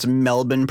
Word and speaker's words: Melbourne: I know enough about Australian to pronounce Melbourne properly.